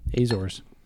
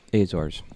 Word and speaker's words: Azores: Azores. – Azores. Yeah. – They have an E, but they don't pronounce it.